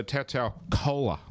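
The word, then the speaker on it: Tolutau Koula: No turbo, so Tautau Cola, I'm told, is like Coca-Cola, is how you say his name.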